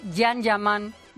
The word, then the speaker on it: Can Yaman: se escribe Can Yaman, pero se dice Yan Yaman, ya que en turco no existe la fonética de la letra C.